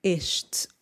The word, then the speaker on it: este: E só aqui uma pequena nota na palavra este, ok? Nós temos um s t -E. Neste caso, é necessário pronunciarmos o E, ok? Porque o E e o S fazem parte da sílaba tônica.